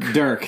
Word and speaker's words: Dirk: Dirk, thank you, Dirk. It rhymes with jerk.